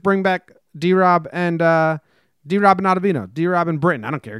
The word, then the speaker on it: Ottavino: If they're not interested in bringing back D-Rob, then is it Ottavino? Is that how you say it, Ottavino? – Ottavino. Hey, we're out of vino.